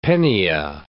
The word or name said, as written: pennia